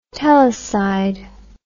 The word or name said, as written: telecide